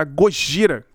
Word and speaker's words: Gojira: Em 2001, então, o Godzilla foi legalmente obrigado a mudar o seu nome, e eles acabaram mudando para Gojira, que é a pronúncia de Godzilla em japonês.